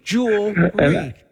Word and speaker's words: jewelry: It's not jewelry. Just look at the word. It's jewelry. Jewelry. Two syllables, not three.